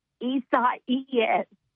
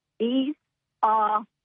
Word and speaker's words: Isaias: It's the way you actually spell it, it is I-S-A-I-A-S. So it looks like Isaiah's. – Okay. – But technically, the Hurricane Center says it's Esau-ies.